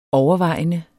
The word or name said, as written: overvejende